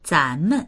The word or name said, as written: zan2men